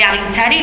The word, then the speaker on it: Agintzari: Ellas son María y Josune, de Aguinchari, ¿lo digo bien? – Sí.